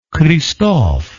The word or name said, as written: cristophe